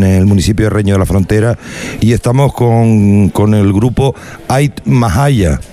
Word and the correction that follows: Ait Nahaya: Creo que lo he dicho bien. Ait Mahaya. No. Ait Nahaya.